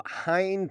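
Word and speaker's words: Llŷn: The island known as Bardsey, located approximately 2 miles off the coast of Hein Peninsula in North Wales, at least that's how I've been led to believe it's pronounced, spelled L-L-Y-N, has limited electricity provided by solar panels, which can apparently run a light, refrigerator, and internet router.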